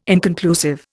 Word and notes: inconclusive: Indian

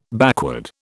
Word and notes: backward: British English

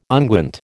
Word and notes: unguent: American English

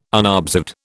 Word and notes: unobserved: British English

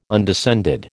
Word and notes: undescended: American English

